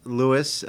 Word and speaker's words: Louis: The Apostolic Nuncio, Archbishop Christophe Pierre, being from France, noted that the spelling of Louis is also the French spelling, not L-E-W-I-S, but L-O-U-I-S. And so in France, you would pronounce it Louis.